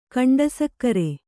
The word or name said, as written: ಖಂಡಸಕ್ಕರೆ